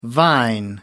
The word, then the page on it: vine: American English